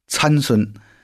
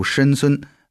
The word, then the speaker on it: 参孙: 这个字呢，最早出现在士师记的 14 章，在申孙的婚礼上，申孙曾给参加婚礼的非利士人出了谜语。我们说到了申孙啊，可能有的弟兄姊妹念作参孙，不过我也仔细查了他这个原文的发音，还是读申孙是这个对的啊。